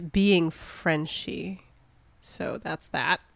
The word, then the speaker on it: Frenshe: It's spelled F-R-E-N-S-H-E. French, but it's apparently pronounced Frenchie.